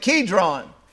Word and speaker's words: Kidron: The name of this brook is, you can pronounce it several ways, but Kidron.